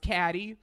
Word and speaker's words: Katy Perry: My safe word is go to hell, Katy Perry, pronounced Caddy.